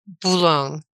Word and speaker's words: Boulogne: It's like Boulogne. – Okay. – Like cologne with a B.